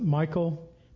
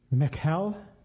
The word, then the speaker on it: Michal: I always have said Michal. Some people pronounce it Michael. My middle name is Michael. That's not how I spell my middle name, so I have a hard time saying it's Michael in the Hebrew. It's Michal or Michal.